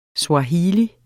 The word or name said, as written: SWAHILI